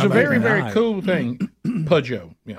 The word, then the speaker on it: Peugeot: So she says you pronounce it Peugeot. – Peugeot. – P-E-U-G-E-O-T. Peugeot.